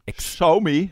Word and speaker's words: Xiaomi: Jeg har jo kjøpt min første smarte enhet jeg også, som en Xiaomi. Jeg skjønner ikke, skal du uttale sånn? – Xiaomi, ja.